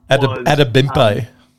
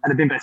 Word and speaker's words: Adebimpe: I've been butchering his surname, so I'm just going to call him Tunde. That he Adebimpe. – Adebimpe, sorry about that.